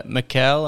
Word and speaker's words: Micael: I'm your host, and today I have my Swedish brother, Micael. How are you, Micael?